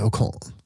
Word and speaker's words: Ocon: Allez Ocon. Très bien. Hij reed très bien. Point. 10 point. Nee? – Ja, gewoon vierde plek. – Quatrième position. Ja. Nou, het leuke dat Ocon was vooral dat het team tegen hem zei van...